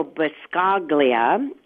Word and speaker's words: Buscaglia: And you open chapter 2, Cherish Your Partner, with, I think, a wonderful quote from Leo Buscaglia. Am I pronouncing his last name right? Something. It's close. – Sounds good to me.